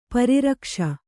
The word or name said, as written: ಪರಿರಕ್ಷ